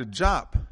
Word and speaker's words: Diop: Dr. Diop, and I know it's many people say Diop because it's D-I-O-P, Diop, but it's pronounced Diop.